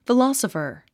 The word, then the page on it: philosopher: filάsəfə r; フロォソファ